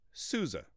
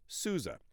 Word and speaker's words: SUSE: It is pronounced SUSE, like the sousaphone, or John Phillips SUSE, a different spelling, but that's how it's pronounced, SUSE.